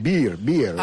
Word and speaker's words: beer: un beer garden, que le llaman. – Big garden. – Beer. – Ah, beer garden. – Pronunciation, no, beer please.